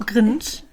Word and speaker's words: cringe: Cringe. – Ihr seid alle beide gar nicht so falsch. Es wird cringe ausgesprochen.